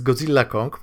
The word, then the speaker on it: Godzilla x Kong: Godzilla X Kong, przy czym X jest silent z tego, co słyszałem, więc Godzilla Kong po prostu.